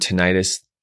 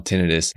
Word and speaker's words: tinnitus: If you pronounce the word tinnitus, that's okay. You can pronounce it either way.